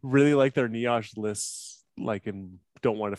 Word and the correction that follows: niche: Was that you really mispronouncing the word niche? – Yes. – Okay. – Yeah. – I appreciate that you pronounce the word niche like it's a type of bread.